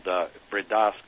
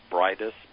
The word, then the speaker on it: Bridas: Called Bridas Corporation from Argentina, and I always say it phonetically because people can find it easier. It's Bridas, B-R-I-D-A-S, but in Spanish they pronounce it Bridas.